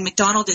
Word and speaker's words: McDonald: And McDonald is spelled M-C-D-O-N-A-L-D.